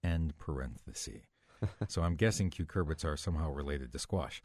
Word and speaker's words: cucurbits: Tiffany has posted this question on Facebook, and the word she's used is spelled C-U-C-U-R-B-I-T-S.